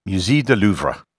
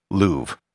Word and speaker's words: Louvre: In French, the name would be pronounced Musée du Louvre. However, that is not how I'm going to pronounce it for the rest of this episode. I'll be using the most standard English pronunciation of the museum, Louvre. The reason for this is that this podcast is in English, and Louvre isn't how it's commonly pronounced in English.